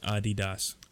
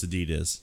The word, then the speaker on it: Adidas: I Googled it, and yeah, the correct pronunciation is Adidas. – Adidas. – I will never say that. – No, it's Adidas.